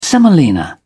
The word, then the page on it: semolina: British English